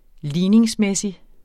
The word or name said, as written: ligningsmæssig